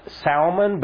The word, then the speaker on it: Salmon: And a fellow named George, I think it's pronounced Salmon, but it's spelled like salmon, which I love.